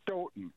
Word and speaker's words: Stoughton: How do you say it? – You actually, the second time you said it right, Stoughton. – They pronounce it Stoughton. – Like Houghton, Michigan.